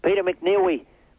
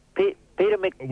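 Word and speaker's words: Peter McNeeley: Peter McNeil. Peter McNeely. Peter McNeely. – We don't have the time. – S-A-F-M.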